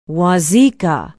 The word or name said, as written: wauzeka